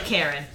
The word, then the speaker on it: Karen: So the Karen people, spelled like Karen, but pronounced Karen.